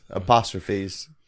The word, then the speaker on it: apostrophes: I try not to use astrophysics, but... – Did I say that wrong? – No. – Astrophy? – Yes. – Apostrophes.